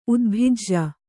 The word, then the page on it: ಉದ್ಭಿಜ: udbhija